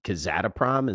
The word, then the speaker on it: Kazatomprom: And you had initiated a position in what, Kazatomprom? Is that how you say it? – Yeah.